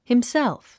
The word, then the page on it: himself: himsélf; ヒムセルフ